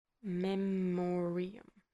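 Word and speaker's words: Memoriam: Wow, I butchered that word. What I can tell you, though, is that in 1850, the British poet Lord Alfred Tennyson, Tennyson wrote in I did it again. In memorandum. Wow. Memorium. Memorium. Memorium. I know that. What's wrong with me? In... I already can't. It memorium, I know how to say this word.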